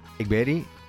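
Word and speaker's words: Egberg: Sí, está Pontus Egberi. – Que me decía que lo que se escribe con ERG al final en sueco se pronuncia Eri o Beri.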